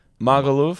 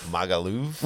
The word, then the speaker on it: Magaluf: No, M-A-G-A, not a great, L-U-F. Magaluf. – Magaluf.